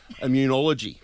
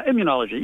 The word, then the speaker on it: immunology: No, immunology. – You were close. Immunology.